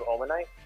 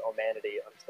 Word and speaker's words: Omanyte: Wait, is it the evolution of Omanyte? – Yes. – Okay, I know what that looks like. Fun fact for viewers at home, I used to pronounce Omanyte as Omanity until Mom had corrected me.